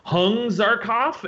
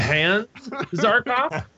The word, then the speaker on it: Hans Zarkov: I was convinced he kept pronouncing his name as Hung Zarkov instead of Hans Zarkov. So,